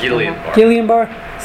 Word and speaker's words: Guillain-Barré: Guillain-Barré. Is that the way? Is that the correct pronunciation? – Guillain-Barré syndrome.